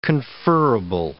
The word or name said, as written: conferrable